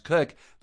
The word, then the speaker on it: Koch: Or my last name, spelled K-O-C-H, which we pronounce Cook, that's just bizarre, which is why I go by Dr. Kathy professionally.